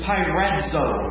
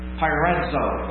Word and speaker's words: peirazo: It's spelled different than it sounds. – C'est écrit autrement que ça sonne. – P-E-I. – P-E-I-R-A-Z-O. – Pyrazo.